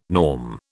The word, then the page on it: norm: British English